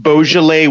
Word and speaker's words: Beaujolais: So I had this bottle of, I always feel like I'm pronouncing this wrong, Beaujolais wine.